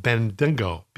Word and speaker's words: Bendigo: B-E-N-D-I-G-O.